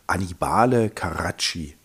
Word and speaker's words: Annibale Carracci: Ich sag noch den Maler, wenn ich ihn richtig ausspreche, mein Italienisch ist immer noch nicht besser geworden, Annibale Caracci.